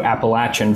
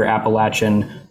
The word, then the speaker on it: Appalachia: Generally, if you're in the north, it's Appalachia. If you're in the south, it's Appalachia.